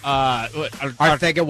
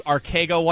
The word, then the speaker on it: Arcega: Arthega Whiteside as opposed to Arcega Whiteside, which is how it's phonetically pronounced. – He's from Spain. – He is from Spain. – Got to get the lisp.